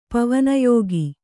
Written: ಪವನಯೋಗ

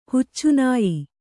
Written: ಹಚ್ಚನೆಯ

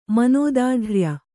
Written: ಮನೋದಾಢ್ರ್ಯ

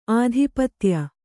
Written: ಆಧಿಪತ್ಯ

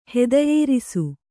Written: ಹುದಿಯೇರಿಸು

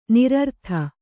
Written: ನೈರ್ಋತ

